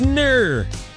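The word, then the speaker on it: gnurr: That term is NUR. NUR with a silent G. G-N-U-R-R.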